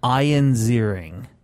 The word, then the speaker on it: Ian Ziering: Ion Vader? – Ian Ziering.